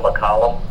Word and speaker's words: McCollum: Yes, my name is Jim McCollum. That's spelled M-C-C-O-L-L-U-M.